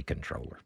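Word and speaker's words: comptroller: Our Texas controller, and I don't know, I never pronounce the P in that. I just say controller.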